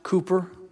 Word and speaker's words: Cowper: It's spelled C-O-W, but it's pronounced Cowper.